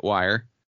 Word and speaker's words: Wir: They put out one record as Wire without the E, just W-I-R, but they still pronounced it Wire.